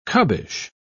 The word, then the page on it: cubbish: British English